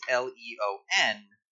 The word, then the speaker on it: Leon: roundabout for Japanese audiences because it's spelled in English. Well, it's spelled internationally as L E O N, but the E makes a long, like an E sound, and well, typically in you know in the way E is taught internationally is as making an S sound, like how it's pronounced, you know, in according to its like French origins. Leon.